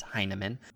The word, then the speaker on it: Heineman: And because his brother is in the majors, he gets a pronunciation guide on Baseball Reference. So I know for a fact he is Heineman.